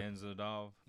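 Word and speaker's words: Gamzatov: But who knows how good, say his name, Gamzadov is? Gamzadov? Yeah. – I keep wanting to say Gamrot, like. – Yeah, Gamer. Gamer. But it's like got a T and a – yeah, it's fucked up.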